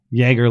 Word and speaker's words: Jaeger: Okay, so we had one from, how do you pronounce this? Is it Daniel Jaeger? Jaeger? – Yeah, Jaeger, like a Jaeger bomb.